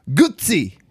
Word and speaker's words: Guzzi: Will you teach him how to say that right? – Guzzi. – There you go.